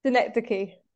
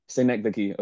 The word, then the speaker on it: Synecdoche: After Almost Famous, I would say, I'm not really sure how to pronounce the name of the movie, but Synecdoche, New York. – Synecdoche. – Synecdoche, okay.